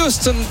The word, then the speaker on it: Houston: nous rappelle qu'il faut prononcer à l'américaine les noms des villes. Houston, Texas, évidemment.